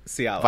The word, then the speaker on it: Cialis: Cialis.